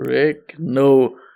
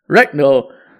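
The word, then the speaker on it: Ragnow: Und Center Frank Reck Rick, Rick, Rick, Rick, Warte, ich hab's doch vorhin richtig ausgesprochen. Reckno. Reckno. Verlängert um vier Jahre bei den Lions.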